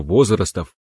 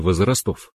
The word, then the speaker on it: возрастов: Правильно возрастов, а не возрастов.